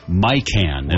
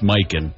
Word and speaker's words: Mikan: 1924 for George Mikan, as they pronounced it there, but it's Mikan. To you and me.